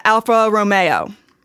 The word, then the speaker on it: Alfa Romeo: He also had the Lamborghini and the Alfa Romeo.